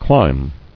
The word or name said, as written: Clime